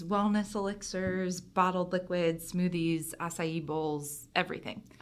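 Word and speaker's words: açaí: I am so impressed with the way you just pronounced acai. It's Did I get it right? – Years. Yeah, I still look at it and I say acacia and then my kids laugh at it.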